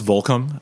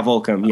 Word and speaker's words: Volcom: And that definitely, I mean, that is exactly why I'm at now, but wait, so it's pronounced Volcom. Never known that. – Yeah. Volcom. Yeah. – I've just always read it, and I've always read it as Volcom. – Yeah. – So that's good to know.